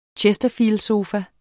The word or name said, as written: chesterfieldsofa